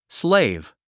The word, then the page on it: slave: sléiv; スレイブ